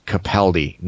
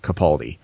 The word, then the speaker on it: Capaldi: It is actually pronounced Capaldi, not Capaldi.